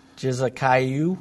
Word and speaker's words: Jezekiah: do you know how to pronounce this word? – Jezekiah. – That sounds perfect.